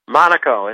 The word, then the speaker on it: Monaco: Monaco.